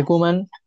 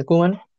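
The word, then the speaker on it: Koeman: Ojalá que el que Kuman, así se pronuncia, ¿no, Jordi? Yo estoy pronunciando aquí como se dice, Kuman. – Sí, sí, sí, correcto.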